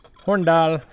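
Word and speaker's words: Hornindal: Do you pronounce the I in Hornadal? I feel like that should answer that question. – Hornadal. – Horndal. – Yes.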